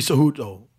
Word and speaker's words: Cejudo: Henry Cejudo. So I said it right. Cejudo.